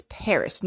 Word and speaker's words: Perris: Paris. No, it's not spelled the same as the heiress named Paris. It's spelled PERRIS, California, just for your information, okay?